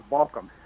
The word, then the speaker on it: Baucom: Well, Baucom.